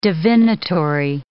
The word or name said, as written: divinatory